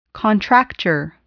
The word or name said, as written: contracture